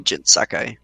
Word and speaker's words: Jin Sakai: Jin Sakai. Is that how you I hope Yes. – that's how you pronounce it. – Yeah, Jin Sakai.